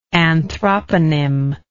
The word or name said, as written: anthroponym